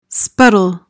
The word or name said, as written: spuddle